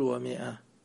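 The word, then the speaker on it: ومائة: ومئة لا تقول ومئة، قول ومئة. هي ومئة، الألف اللي حطوها هذه الزيادة ما حد ينطقها، بعضهم يقرأ 500، 500. الألف هذه زادت، زادوها في الكتابة حتى يفرقون بين المئة وبين فئة أو كلمة أخرى، هذا حرف يكتب ولا ينطق.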